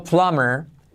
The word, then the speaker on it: plumber: Otra palabra, fontanero, plumber. Plumber, ves que la B no se pronuncia plumber, letra clave R, plumber tuvimos que llamar al fontanero para arreglar la bomba que estaba estropeada, ¿vale?